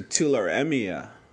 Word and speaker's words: tularemia: Well, how do you spell that? Tularania? – T-U-L-A-R-E, M as in Mary, I-A. M as in Martha, I-A. – So tularemia.